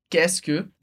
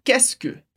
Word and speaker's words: qu'est-ce que: E aqui, super importante, você vai ver no PDF depois, que a gente escreve várias letras para dizer qu'est-ce que, mas a pronúncia é super simples, é só qu'est-ce que.